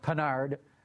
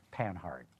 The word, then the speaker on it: Panhard: Panhard, more like the French way of saying it, whereas I think most English speakers would call right. – it Panhard.